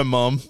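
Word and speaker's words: Mumm: Now, am I saying that right? Is it mum? Mum. – I've always called her mum. – We all say mum.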